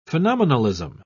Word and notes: phenomenalism: British English

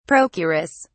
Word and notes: procuress: British English

